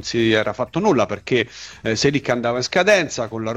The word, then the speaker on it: Çelik: Sto leggendo già che con l'arrivo di Çelik, attenzione, Çelik, diceva bene il direttore, eh? Poi questa sera mi faccio registrare da un collega turco, mi faccio registrare la pronuncia esatta, ma è Çelik, io me lo ricordo bene, il nostro collega amico Dundar Kesapli, che intervenne peraltro una volta anche a Radio Bianconera.